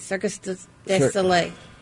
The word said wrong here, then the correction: Cirque du Soleil: Cirque du Soleil.